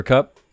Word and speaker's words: Kupp: Cooper Kupp is Amari Cooper. I'm getting all the Coopers mixed up. – Cooper Scooper. – And it rhymes with pup because Amari Cooper's off the pup.